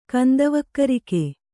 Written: ಕಂದವಕ್ಕರಿಕೆ

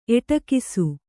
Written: ಎಡಗೂಸು

